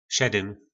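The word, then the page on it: Sedym: nářečí Po našimu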